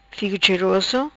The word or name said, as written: Figo Cheroso